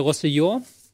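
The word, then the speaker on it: Roussillon: Ähm, ein Mann, den ich wieder falsch aussprechen werde, Rossellon. Ähm, sehr gut. War mich falsch. – Nee, nee. Achso. Das war gut.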